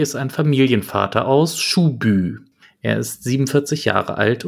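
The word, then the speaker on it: Schuby: Der Ort wird gar nicht Schubi ausgesprochen, sondern Schubü, also hinten mit Hü.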